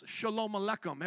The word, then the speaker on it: Shalom Aleichem: The Jewish people say it like this, Shalom Aleichem.